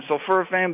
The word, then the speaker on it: sulforaphane: Now, can we talk, I don't even know if I'm going to pronounce this correctly, sulforaphane. – Sulforaphane, yes.